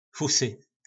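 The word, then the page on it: Fusy: nářečí Po našimu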